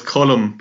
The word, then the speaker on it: Colum: So it's column, but in fairness, it could be column or or any other number of pronunciations depending on what your parents decided when you when you were named.